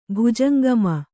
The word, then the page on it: ಭುಜಂಗಮ: bhujangama